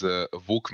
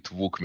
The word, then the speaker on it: Vuk: So that is how you pronounce my name in Serbian. I don't know if there is a way how to pronounce that in English, but let's just stay with Vuk Milošević.